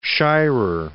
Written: Shirer

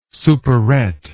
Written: superette

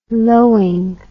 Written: lowing

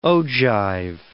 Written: ogive

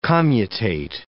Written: commutate

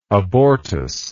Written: abortus

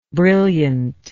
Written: brilliantness

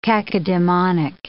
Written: cacodemonic

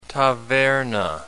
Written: taverna

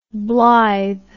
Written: blyth

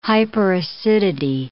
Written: hyperacidity

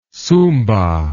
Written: sumba

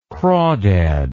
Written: crawdad

